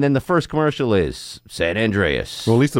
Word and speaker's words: San Andreas: how, San Andreas. San Andreas. That's how you pronounce it.